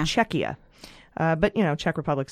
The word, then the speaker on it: Czechia: According to a patron who is in the Czech Republic, Czech Republic is still a valid name, but the pronunciation is Czechia.